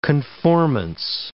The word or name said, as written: conformance